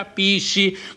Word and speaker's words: PSSI: Si pronuncia Pissi, come abbiamo sentito prima da Lino.